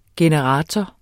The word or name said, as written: generator